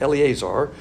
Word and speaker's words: Eleazar: is how you pronounce it, Eleazar.